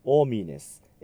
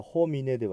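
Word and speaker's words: homines: クイプロプテルノスオミネス、 H の音を発音しません。ですからホミネではなくオミネになります。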